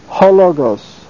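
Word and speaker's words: hologos (ὁ λόγος): And notice the pronunciation. It's hologos. Ho is a proclitic, has no accent of its own, so is pronounced with the word that follows.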